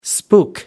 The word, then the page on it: spook: American English